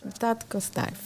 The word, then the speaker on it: Dadgostar: Jag tycker Nooshi Dadgostar, jag är så dålig på att uttala hans namn. Och det är ingen härskarteknik, det är bara talfel. Hur uttalar man det? Dadgostar.